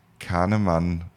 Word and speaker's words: Kahneman: der Psychologe David Kahnemann, so wird es in Deutsch ausgesprochen, Englisch, ähm, weiß nicht, ob er sich Kahneman, Kahneman oder sowas spricht, der hat ein Buch geschrieben, äh, gab es den Wirtschaftsnobelpreis, Schnelles Denken, Langsames Denken.